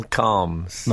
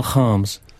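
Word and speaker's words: Kharms: How's this for pronunciation? Daniel Calms. – Not very good. – Oh. – It's Daniel Harms.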